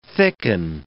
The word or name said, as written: thicken